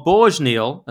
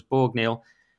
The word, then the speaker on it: Borg-Neal: All right, well, let's kick off with our first big story then, which is Carl Borg-Neal, as I'm reliably informed it's pronounced. I thought it was Borg-Neal.